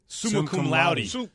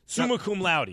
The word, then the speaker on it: summa cum laude: Summa cum laude. Summa cum laude.